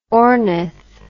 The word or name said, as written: ornith